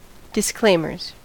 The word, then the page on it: disclaimers: American English